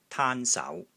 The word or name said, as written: TAAN1 SAU2